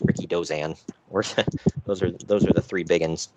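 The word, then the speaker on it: Rikidozan: his name was pronounced Ricky Dozan.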